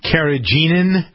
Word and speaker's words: carrageenan: You're going to have to help me with the pronunciation of this as well. Carrageenan. It's an emulsifier. – I believe that is correct.